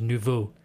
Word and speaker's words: nouveau: Nouvelle and nouveau.